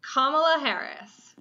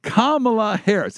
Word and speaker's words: Kamala Harris: Kamala Harris. – Kamala Harris.